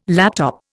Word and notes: laptop: Indian

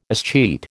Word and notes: escheat: American English

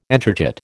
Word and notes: entrechat: American English

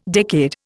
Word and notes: decade: Indian

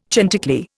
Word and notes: chanticleer: Indian

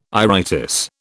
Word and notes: iritis: British English

